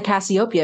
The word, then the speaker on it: Cassiopeia: She's like, you mean the Cassiopeia? Because I was pronouncing it wrong.